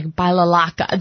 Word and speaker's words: balalaika: It's a Russian instrument, and it's spelled – It's pronounced like balalaika. There's like about 7 different vowels in it, and it's like B-A-L-A-L-A-I-K-A.